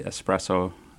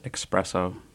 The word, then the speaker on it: Espresso: Well, the correct way to pronounce it is definitely Espresso, not Espresso.